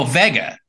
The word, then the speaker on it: Vega: Oh, Vega.